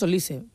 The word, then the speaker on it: Olise: Olise. – Bueno, yo le he escuchado a él decir Olise. – Yo estoy convencida que es Olise, porque...